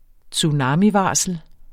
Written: TSUNAMIVARSLET

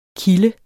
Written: kilde

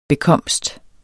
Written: bekomst

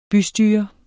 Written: bystyre

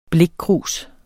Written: blikkrus

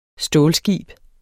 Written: stålskib

